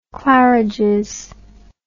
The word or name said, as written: claridges